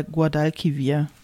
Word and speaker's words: Guadalquivir: Ich glaube, ich habe Guadalquivir gesagt, es ist aber der Guadalquivir. Ich hoffe, ich habe es richtig ausgesprochen.